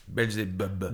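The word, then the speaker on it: Beelzebub: C'est comme ça, on prononce comme ça Belzebub.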